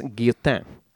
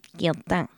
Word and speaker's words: Guillotin: Is it spelled the same way? – Uh, there's no e at the end. It's G U I L L O T I N, and I think I I think I kind of nailed that pronunciation.